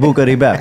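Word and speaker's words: Buca di Beppo: That's why you pronounce it in Italian, Buca di Bep.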